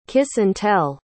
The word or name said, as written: kiss-and-tell